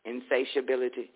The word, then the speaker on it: insatiability: That's how you pronounce it. S-A-T is shay, insatiability.